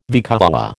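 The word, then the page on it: vicuña: American English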